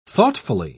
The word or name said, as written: thoughtfully